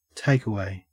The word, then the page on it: takeaway: Australian English